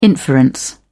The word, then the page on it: inference: British English